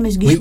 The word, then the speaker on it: Mesguich: on prononce comme ça, Mesguich aussi.